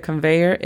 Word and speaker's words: conveyor: La pronunciación fonética de conveyor es K-A-N-V-E-Y-R. Conveyor. – Conveyor belt. – Yes, belt. Es B-E-L-T.